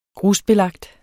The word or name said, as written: grusbelagt